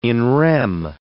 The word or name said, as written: in rem